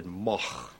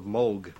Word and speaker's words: Moog: In Germany, it's a fairly common name, and there it's pronounced Moog. In Great Britain, English-speaking countries, people see the double O and they say Moog.